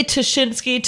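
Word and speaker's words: Ptaszynski: Well, I say Tashinsky. Dan Schreiber, who hosts our podcast, says Jasinski, which is a mispronunciation.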